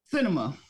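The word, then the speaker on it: Sinema: Cinema. – Like Senator Cinema. Not spelled like the actual theater, no. But cinema. Like, she is driving all Democrats absolutely insane.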